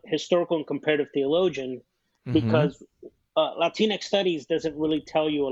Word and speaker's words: Latinx: Latinx, did I get that right? Yeah, it's Latinx Studies and Religion, the title.